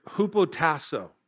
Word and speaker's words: hupotasso: The Greek word, and it's really an interesting word for submission here, is hupotasso. It's spelled H-U-P-O-T-A-S-S-O.